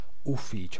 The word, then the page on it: byrå: /ˈbyːˌrɔ/; /ˈbyːrɔ/; /bʏˈroː/; /byroː/